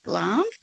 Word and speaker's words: Glamis: It's spelt G-L-A-M-I-S, Glam's, but it's pronounced Glam's thistle.